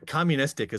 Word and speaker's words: communistic: Communistic is a word I keep reading, which I wasn't hip to before. – He loved to pronounce it communistic. He didn't do the long U.